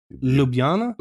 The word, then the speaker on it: Ljubljana: Ljubljana? – Ljubljana, yeah.